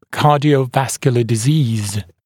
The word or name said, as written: cardiovascular disease